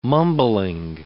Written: mumbling

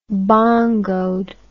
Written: bongoed

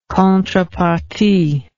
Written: contrepartie

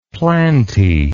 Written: plantae

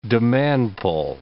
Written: demand-pull

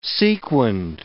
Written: sequined

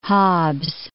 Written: Hobbes

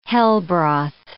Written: hellbroth

